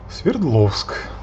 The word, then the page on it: Свердловск: svʲɪrˈd